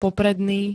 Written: popredný

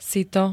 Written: sito

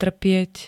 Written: trpieť

